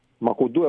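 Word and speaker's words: Makoundou: Μακουντού, εγώ λέω γιατί είναι Γάλλος. – Μην μιλάς. – Ιωάν Μακουντού. – Μακουντού. – Μακουντού. – Μα είναι. – Και ο Ντιάλο, όχι Ντιάλο που το λέμε εμείς. – Ο Μακουντού. – Μπορεί να το πεις και άστο Ντιάλο. – Όχι, ο Ντιάλο είναι. Ο Μακουντού είναι Γάλλος και τα γαλλικά τονίζονται στη λίγουσα.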